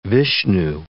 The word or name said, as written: Vishnu